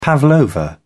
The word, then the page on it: pavlova: British English